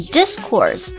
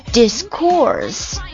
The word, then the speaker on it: discourse: discourse discourse，它有两个读音，名词的时候重音在第一个字节上面， discourse 名词， discourse 动词。